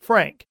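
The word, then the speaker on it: Franc: When a girl came along and her mother didn't like using Francis as a compromise, they changed the K to a C and still pronounced it Frank.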